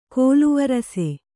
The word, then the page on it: ಕೋಲುವರಸೆ: kōlu varase